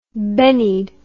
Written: bennied

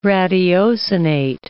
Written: ratiocinate

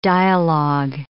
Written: dialogue

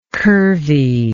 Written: curvy

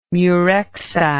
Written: murexide

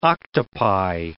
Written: octopi

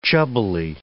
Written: chubbily

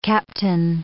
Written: captain

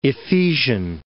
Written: Ephesian

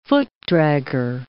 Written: footdragger

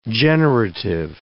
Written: generative

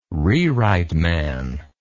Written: rewrite man

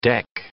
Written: deck

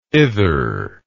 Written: ither

